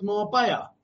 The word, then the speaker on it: Marbella: It's pronounced Marbella.